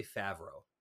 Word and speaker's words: Favreau: Favreau. – I haven't gotten far enough in Sopranos when they say his name. – I'm going to say Favreau.